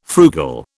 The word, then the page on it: frugal: British English